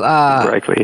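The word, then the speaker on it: Raichle: And you've quoted lots of different, Colin Martindale and Marcus Reichel, if I'm saying his name right, Reichel, who... – Reichel, yeah.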